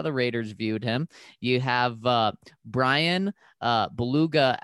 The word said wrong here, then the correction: Bulaga: out there Belaga, yes, thank you.